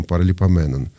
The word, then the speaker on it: паралипоменон: Марта От пишет, спасибо, что произносите с благозвучным ударением паралипоменон. Да, в общем, по-гречески так и положено, поэтому по-русски также и произносим.